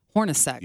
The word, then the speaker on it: Hornacek: I would like to know opinion on Horacek. – Hornacek. – Yeah, I know. It's just Hornacek. How it's spelled.